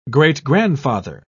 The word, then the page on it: great-grandfather: British English